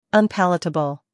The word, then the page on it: unpalatable: American English